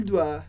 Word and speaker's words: doit: Je dois, tu dois, il doit, elle doit, on doit. A gente escreve diferente, com S para je e tu, com T para il, elle, on, mas a pronúncia é exatamente a mesma.